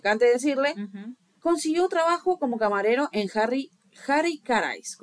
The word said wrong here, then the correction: Harry Caray's: ¿Cómo se dice eso? Ese es Harry Caray's.